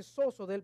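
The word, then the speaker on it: soso (σῴζω): Esa es la palabra, eso es como se pronuncia, soso, viene del griego soso.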